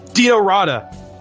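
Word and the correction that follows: Dino Rađa: You butchered his name. It's Dino Raja, but yes, that's correct.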